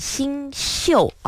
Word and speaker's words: 星宿: 哎，没错，他就问我这个星宿到底该怎么写。其实这个宿字啊，它就是和宿舍的宿字是同个字。不过呢，我们在这里古人的星座呢，其实应该念成星宿啊。